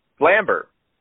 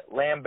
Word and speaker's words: Lambert: I mean, I think is it Christopher Lambert or is it Lambert? – Lambert. I think it's a silent T. I think it's like Lambert or something.